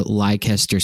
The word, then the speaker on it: Leicester: I was on the subway platform in London, and I was trying to get to Leicester Square, and I asked this lady, can I get to Leicester Square? And she goes, Leicester. And I was like, oh, right, yeah, sorry, that one. It's very hard for Americans to pronounce.